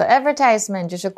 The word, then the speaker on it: advertisement: So advertisement Now, um, I think some British people pronounce it as advertisement. – Advertisement. – But yes. I don't say that. I say advertisement.